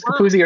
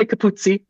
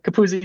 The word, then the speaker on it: Capuzzi: Yep, James Capuzzi, or you could say Capuzzi if you want to be really Italian, but Capuzzi is fine.